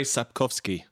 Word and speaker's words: Sapkowski: Andrei Sapkowski? Did I say that correctly? – Andrei Sapkowski.